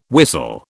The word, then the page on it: whistle: British English